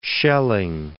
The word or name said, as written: Schelling